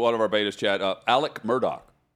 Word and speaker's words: Alex: Alex Murdaugh, but it's Alec is what everyone says.